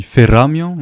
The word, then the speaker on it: Feramia: Et tu représentes Feramia, c'est ça? C'est comme ça qu'on prononce? – Oui, on dit Feramia, mais Feramia, ça sera...